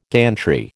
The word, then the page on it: gantry: American English